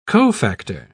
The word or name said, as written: cofactor